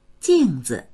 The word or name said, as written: jing4zi